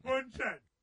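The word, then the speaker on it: Bündchen: I can't pronounce her last Bunchen. – Name. – Bunchen. – That's her. – Giselle Bunchen.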